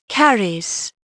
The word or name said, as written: CARRIES